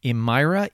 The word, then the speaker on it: Emira: I think actually, and this is kind of crazy, but one of his top 10 games is called Emira, E-M-I-R-A, I believe it's pronounced.